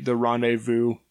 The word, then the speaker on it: rendezvous: William Ashley was a fur trader, and he popularized the rendezvous as a concept. And in case you're wondering, rendezvous is an old French word that means the Z is silent.